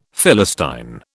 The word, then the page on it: Philistine: British English